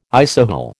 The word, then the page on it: isohel: American English